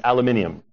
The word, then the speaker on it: aluminium: I was mentioning that while I was in England, and I had to change to the proper pronunciation, aluminium. You might go, really? Yes. Across the pond, it's aluminium.